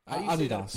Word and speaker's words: Adidas: Adidas. – Apparel company. – Adidas. – Adidas? – Yes, of course. – Adidas.